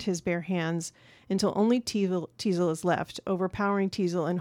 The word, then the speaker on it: Teasel: Teasel, which rhymes with weasel, does not.